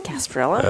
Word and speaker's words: Gasparilla: No, I said Gasparilla. – Okay. – Just a soft G.